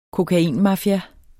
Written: kokainmafia

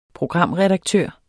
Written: programredaktør

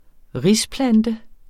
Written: risplante

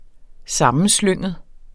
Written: sammenslynget

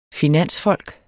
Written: finansfolk

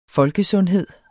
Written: folkesundhed

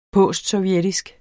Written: postsovjetisk

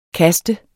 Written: KASTENS

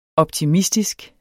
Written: optimistisk

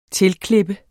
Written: TILKLIPPEDE

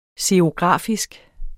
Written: xerografisk

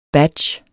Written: badge

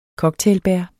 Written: cocktailbær

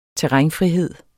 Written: terrænfrihed

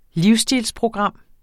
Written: livsstilsprogram

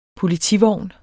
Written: POLITIVOGNE

